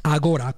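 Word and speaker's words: Agorà: Agora, con l'accento sulla A, sulla A.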